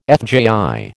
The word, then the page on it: FJI: American English